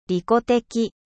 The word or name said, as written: egoiste